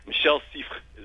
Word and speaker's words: Michel Siffre: Michel Siffre is the French way of saying it.